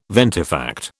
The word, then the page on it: ventifact: British English